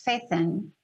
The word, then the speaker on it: Fethon: I first want to know, do I say your name right? Fethon? – Yes. – It's Fethon. Yes. Okay.